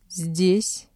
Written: здесь

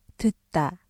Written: 듣다

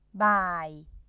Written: บ่าย

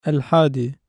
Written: اَلْحَادِي

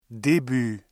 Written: début

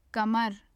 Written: kamar

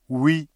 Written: oui

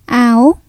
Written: áo

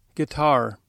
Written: guitar

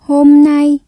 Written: hôm nay

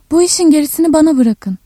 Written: geri

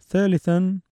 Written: ثَالِثًا

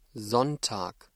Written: Sonntag

